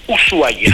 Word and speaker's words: Ushuaia: Es Ushuaia.